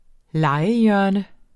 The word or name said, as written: legehjørne